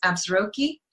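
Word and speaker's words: Absarokee: And so it's spelled differently than it sounds. And so it's Absaroki.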